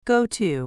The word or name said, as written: go to